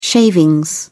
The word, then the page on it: shavings: British English